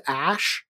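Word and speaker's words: Æ: Like A-E, which apparently, it's like Elvin, and it's pronounced Ash.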